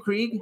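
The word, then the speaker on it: Krige: Is that how you pronounce her name, Krieg? – Yes, she's the Borg.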